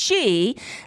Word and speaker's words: Xi: And I see I call it Shitter because it's X-I-T-T-E-R. If President Xi, spelled X-I, is pronounced she, then X-I-T-T-E-R is Shitter.